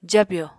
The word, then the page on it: jabyò: apatani